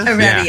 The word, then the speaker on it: Heredia: For those, my name doesn't have an H. – Oh, it's Heredia. – Heredia. Yeah. – Silent H. – The H is silent.